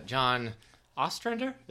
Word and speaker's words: Ostrander: And it's John Ostrander? Is that how you would say his name? – Sure.